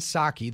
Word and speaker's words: Psaki: Her name is Jen Psaki. The P is silent.